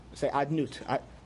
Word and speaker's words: Adnut: you don't pronounce that. You say Adnut.